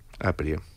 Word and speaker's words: Aprie: El disco nuevo, que es el que estamos presentando, se llama Aprie. – Aprie. – Con acento en la primera, en sí.